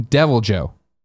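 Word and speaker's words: Deviljho: Titan Meteor writes in and says, just to try to help settle it, the H is silent when saying Devil Joe. – Okay, Devil Joe. – Devil Joe. Just leave out the H.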